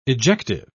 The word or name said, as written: ejective